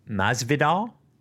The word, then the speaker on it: Masvidal: M-A-S-V-I-D-A-L. Masvidal? Does that sound right to you? – Sure. – Sure? – Sounds right. – Okay, so Masvidal and U-S-M-A-N. – Usman. – Usman? Masvidal and Usman?